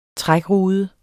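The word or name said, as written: trækrude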